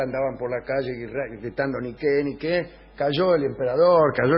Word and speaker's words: Niké: Niké quiere decir victoria, no se dice Nike, se dice Niké, y por eso se llaman así las zapatillas.